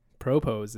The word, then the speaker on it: propose: I was thinking about I'm going to propose. – That's it. That's how you say it. – Accent on the pro.